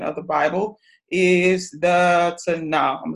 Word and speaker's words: Tanakh: So in your book on page seven, talks about the Tanakh. The K is silent um, before.